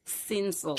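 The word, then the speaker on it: Cinsault: With Neil Ellis, it's a red blend 2024, a blend built on Shiraz and how do you pronounce it? Cinso or Ginso? – Cinso. – Not Italian. – It's Cinso. A great varietal. – No, not Chinese either. Cinso.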